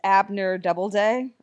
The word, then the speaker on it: Abner Doubleday: There's a proboscis monkey named Paul, who is Abner Doubleday.